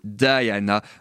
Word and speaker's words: Diana: Oh, je t'en prie, reste auprès de moi Diana ou Diana, euh, comme on le prononce, vous l'avez compris, aux États-Unis.